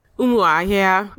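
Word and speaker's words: Umuahia: What's the proper pronunciation? – Umahia.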